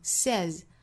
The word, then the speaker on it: says: 后面 says 这个词要多讲一句 ,say 原型本身是双元音，读成 say, 它变成第三人称单数后面加了 s 呢，变成了单元音，读成 says 是读 a 的发音，